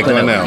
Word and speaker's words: Glenelg: do they pronounce it Glenelg? – Yeah, Glenelg.